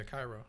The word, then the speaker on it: Cairo: Cairo is that how you say it? Cairo? – Yeah, Cairo.